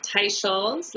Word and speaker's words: Teicholz: It's pronounced Teicholz, like a tie you wear, and Dr. Scholl's shoes.